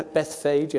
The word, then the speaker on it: Bethphage: As they approached Jerusalem and came to Bethphage, I always can't say that properly, T-H-P-H together, thank you, that's close enough, yeah. Bethphage, yeah.